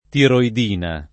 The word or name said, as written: tiroidina